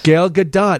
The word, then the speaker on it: Gal Gadot: Gal Gadot is exactly how you pronounce her name. She's on this list. The Israeli actress who starred... – Gal Gadot? – Gal Gadot, yes. But people, her last name is sometimes mispronounced with the silent Gadot. – T. Yes, but it is Gadot.